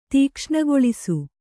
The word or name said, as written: ತೀಕ್ಷ್ಣಗೊಳಿಸು